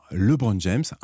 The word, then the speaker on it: LeBron James: On dit bien LeBron James, un peu à la française, c'est contre-intuitif, mais c'est comme ça que ça se prononce, et ça s'écrit L majuscule, E, un B majuscule au milieu du mot, R-O-N.